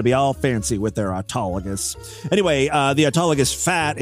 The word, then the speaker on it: autologous: How do you pronounce it? Hold on a second. I'm going to go right to Google. – Autologous. – Autologous. Boy, I butchered that.